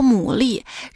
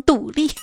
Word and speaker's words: 牡蛎: 这大海当中啊，有种贝类生物叫牡蛎。然而我小时候不够努力，总是把它读成独立。